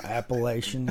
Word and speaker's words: Appalachian: I mean, it rolls even better when you pronounce it right. You know, Appalachian intelligence.